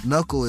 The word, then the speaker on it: Knuccl: Knuckle is spelled K-N-U-C-C-L.